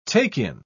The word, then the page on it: take-in: British English